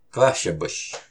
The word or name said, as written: Qáhshapush!